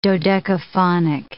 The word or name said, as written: dodecaphonic